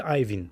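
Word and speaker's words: Iovine: С Джими Айовин. Айвин, да. Айвин така, ли? Да, така му е произношението.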